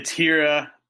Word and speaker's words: Hiura: What's your outlook on Keston Hiura? How do I properly say his name? – It's Hira, and he's extremely talented.